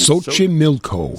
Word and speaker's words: Xochimilco: Xochimilco. I think that's my favorite, my current favorite Mexican restaurant. – You've mentioned this before. – How do you say that again? – Xochimilco. Xochimilco.